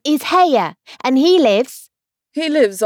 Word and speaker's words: Haigha: His name is Haigha. He pronounced it so as to rhyme with Maya.